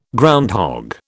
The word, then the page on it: groundhog: British English